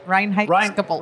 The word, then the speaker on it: Reinheitsgebot: Heinz Gebrut, right? – Yeah. – Heinz Gebrut. How do you say that again? – Reinheitsgebot.